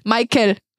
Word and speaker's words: Meikel: M-E-I-K-E-L. Michael. Weil wir dann noch so, okay, klingt wie Michael,